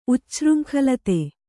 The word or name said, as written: ಉಚ್ಛೃಂಖಲತೆ